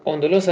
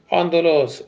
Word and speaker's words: اندلس: در سده 15 میلادی اندلس از دست مسلمانان خارج شد. اندلس، اندلس، تلفظ هر دوش درسته.